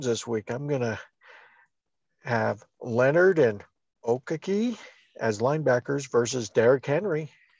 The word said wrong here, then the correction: Okereke: Okereke is the new pronunciation.